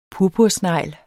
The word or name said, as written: purpursnegl